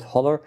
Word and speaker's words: HOLA: So the best paper, this is a paper called, depending on how you want to pronounce it, HOLA. – HOLAR. – Tim Dwyer presented that, and he called it HOLAR.